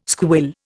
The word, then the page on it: squill: Indian